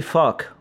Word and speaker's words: FVCK: I'm talking about the new one by Demi Lovato, Holy Fuck, or Holy Favik, because it's spelled F-V-C-K for reasons that I'm sure she has.